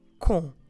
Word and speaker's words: com: C-O-M. Com. In order to pronounce correctly this preposition, don't forget to not close your lips at the end. It's a very nasal sound, but we should leave our lips open.